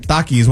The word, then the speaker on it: Takis: The fact that Takis, one of my favorite chips, now has two new cancer-causing contaminants. That's how you say it, right? – Yep.